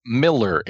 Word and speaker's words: Millar: It's Miller. It's pronounced the same as Frank.